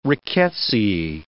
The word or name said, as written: rickettsiae